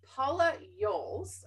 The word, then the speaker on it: Yolles: I'm your host of GYSTTA Live TV, but I'm going to hand it over to Paula Yolles, and I hope I pronounced that properly.